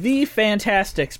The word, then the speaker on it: The Fantasticks: So it's called Fantastics. Um, it's called The Fantastics, but it's spelled S T I C K S.